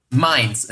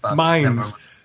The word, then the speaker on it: Mainz: Joining me by phone, a scientist from the University of Mainz, am I mispronouncing that? – Mainz, it is. – Mainz.